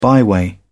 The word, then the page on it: byway: British English